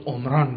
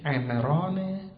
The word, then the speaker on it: عمران: اگه بخواد عربی بشه با چطور تلفظش کنیم؟ باید عمران بگیم، مهندسی عمران میگید، بالاخره این میشه عمران بر وزن فعلان میشه مصدر عربی، یعنی آبادی، مثل فرقان این میشه مصدر من، اما این فعلانه، این امرانه و این عربی نیست.